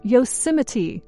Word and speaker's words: Yosemite: It is not Yosemite or Yosemite, but rather Yosemite. At the end, don't forget to say the T.